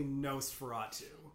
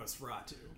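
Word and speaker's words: NOS4A2: I don't know what the proper pronunciation of it is, but it's essentially Nosferatu. – Nice. – Spelled NOS number 4 A number 2, because it's a license plate in the series.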